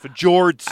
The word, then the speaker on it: fjords: Believe that's how it's pronounced, fjords. Uh, I think you nailed it.